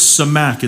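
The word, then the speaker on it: samak (סמך): Now, by the way, whenever you look at that word fixed in the Hebrew, it's actually a word, it's samak is how you say it, S-A-M-A-C-K.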